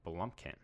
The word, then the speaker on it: Blomkamp: Neil Blomkamp? I can never pronounce this guy. Blomkamp.